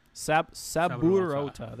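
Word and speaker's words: Saburouta: Okay, by Subarota. So that's who wrote it. I cannot pronounce that, but Saburota. There we go.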